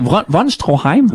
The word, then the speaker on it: von Stroheim: Alors, Erich von Stroheim choisit d'être d'une fidélité absolument irréprochable. – Pourquoi tu choisis toujours des noms que je suis pas capable de prononcer? Von Stroheim? – Oui, Von Stroheim.